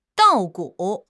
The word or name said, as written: 稻谷